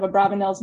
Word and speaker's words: Abarbanel: In many Portuguese documents from Abarbanel's time period, his name is documented as we've been pronouncing it in class, but his own son, Yehuda, actually preferred for it to be pronounced Abarbanel with a B.